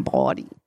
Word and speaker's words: Bawdy: If I have to have people from New York say the name of my show because I'm from the South. I don't say it right. – And how do you spell it? – B-A-W-D-Y, like Mae West body.